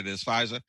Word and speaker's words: Pfizer: So in terms of the vaccine, you know what I found personally interesting is why was it Pfizer? I think I'm pronouncing it the right way. They knew before Pfizer. – Pfizer.